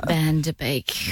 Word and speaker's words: Van Der Beek: James Van Peak. – No. – The correction was an incorrect name. – Wow. – Right person and then pronounce the name wrong. – James Van Der Beek.